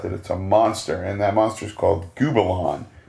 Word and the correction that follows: Gubila: Let me make sure I'm pronouncing that right. Oh, Gubila. Gubila. G-U-B-I-L-A.